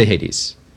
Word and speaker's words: Hades: You did say Hades.